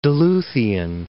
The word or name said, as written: Duluthian